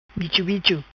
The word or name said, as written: Bicho Bicho